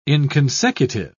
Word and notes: inconsecutive: British English

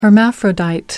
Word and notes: hermaphrodite: American English

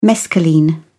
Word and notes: mescaline: British English

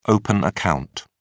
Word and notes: open account: British English

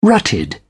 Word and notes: rutted: British English

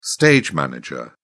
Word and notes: stage-manager: British English

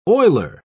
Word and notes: oiler: British English

